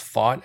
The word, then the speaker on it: Pfaadt: And it is pronounced fought as in I fought you. – Oh. – P-F-A-A-D-T.